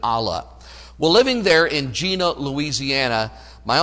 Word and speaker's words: Jena: Uncle Don was raised in a little town called Gena, Louisiana. It's spelled J-E-N-A.